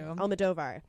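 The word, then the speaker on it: Almodóvar: Almodovar.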